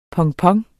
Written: pompon

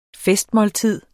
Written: festmåltid

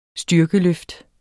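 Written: STYRKELØFT

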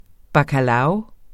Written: bacalao

